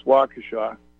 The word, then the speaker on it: Waukesha: Waukesha, Wisconsin. And might be pronounced Waukesha. Who knows? Like Kenosha. – I think it's Waukesha.